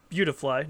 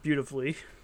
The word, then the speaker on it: Beautifly: Pronounced Beautifly, not Beautifully.